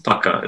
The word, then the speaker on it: pukka: That's Pukka. Pukka is the pronunciation. That one, even I know that one.